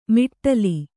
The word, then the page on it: ಮಿಟ್ಟಲಿ: miṭṭali